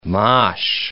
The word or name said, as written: mosh